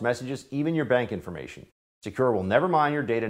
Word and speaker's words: Sekur: That's why you need a new privacy and cybersecurity application tool called Secure. It's spelled S-E-K-U-R.